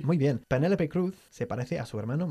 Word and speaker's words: Cruz: O como decimos en inglés, Penélope Cruz. – Penélope. – Igual que Tom Cruise, por cierto. Se pronuncian igual.